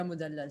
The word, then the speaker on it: Mudellal: Yeah, Sarah Mudellal, Sarah. Yeah, I butcher it in English all the time. Like, I say Moodle.